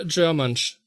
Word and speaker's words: Germansch: Ihr habt ja jetzt, glaube ich, ihr habt ja gestern wieder auf Germansch wie hat das ausgesprochen? Germansch? – Germansch. – Germansch, also wie halt German und German plus French gleich Germansch.